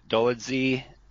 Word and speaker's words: Dolidze: Roman Dolidze.